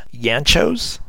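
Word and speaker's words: Yanchus: It's M-A-H-L-S-T-E-D-T. Colors are by Andy, and I believe his last name is pronounced Yanchos. It's Y-A-N-C-H-U-S.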